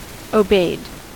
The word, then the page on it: obeyed: American English